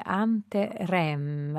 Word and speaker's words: Anterem: Dottor Ermini, nel lontano 1976, insieme a Silvano Martini, lei fondava dunque Anterem, accento così per evocare l'origine etimologica, ce lo dirà lei stesso, rivista di ricerca letteraria, il sottotitolo.